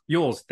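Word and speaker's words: Elwes: Harry Yulis from Princess Bride. – Oh, okay. – I know I'm butchering his name, but I can't. – Cary Yules? – Yules, there we go.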